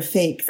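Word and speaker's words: PHEIC: I mean, in order to be declared a fake, that's the way it's pronounced.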